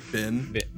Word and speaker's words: been: How do you pronounce the word B-E-E-N? – Ben. – Ben. – Ben, yeah. – Ben. – B-I-N. – So it says as in sit, see, or set.